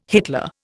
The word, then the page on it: Hitler: Indian